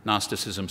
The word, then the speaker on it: Gnosticism: Gnosticism starts with a silent G, in case you want to write that down.